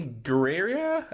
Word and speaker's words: Gurira: Danny Gurira.